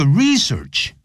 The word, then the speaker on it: research: 这个 research 名词动词都念成 research